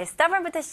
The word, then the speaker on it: stubborn: 네, stubborn부터 시작할게요. stubborn, 고집스러운. 이거는 발음은 stubborn, okay? 첫 번째 음절은 sta, 그리고 두 번째 음절은 태우다 할 때 b-u-r-n, burn, 이렇게 발음하시면 돼요.